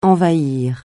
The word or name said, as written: envahir